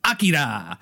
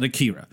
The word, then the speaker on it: Akira: And it was Akira, which is how they say it over and over in the movie. But in America, we call it Akira.